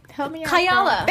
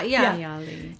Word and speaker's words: Kayali: It's, let me pronounce this correctly, Kiali? – Oh, Kayala. – Thank you. – Kayala, yeah. Her fragrance line. Yeah. – Look at the Middle Eastern girl. Like, yeah, no, I hope I said it right. But yeah. – Kayali. – Kayali, yep.